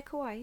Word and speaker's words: Kauai: Speaking of that hurricane, though, so principal photography had started, and you might have to help me with pronunciation. – Yeah. – Kauai? – Yeah, Kauai.